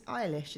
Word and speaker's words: Eilish: It's Eilish, isn't it? – Yeah, I want to pronounce it right.